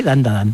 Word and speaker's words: Dandadan: Tenemos Dandadan, creo que lo he dicho bien, Dandadan. – Sí, Dandadan.